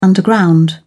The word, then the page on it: underground: British English